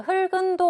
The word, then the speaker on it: 흙은: 자, 흙은 도 제가 방금 발음을 했는데, 기역만 올리면 되기 때문에 흙은이라고 발음을 해야 하죠.